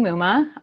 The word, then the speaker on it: Muma: Well, so you can find me at Lindsay Muma. My last name rhymes with Puma. People who...